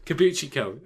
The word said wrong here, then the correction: Kabukicho: Oh, Kabuki. Yeah, eh. Krispy Kreme. Krispy Kreme. – I do it all the time too. Kabukicho. Kabukicho. Kabukicho.